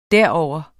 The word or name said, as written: derover